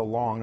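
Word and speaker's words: lo (לא): That's pronounced lo.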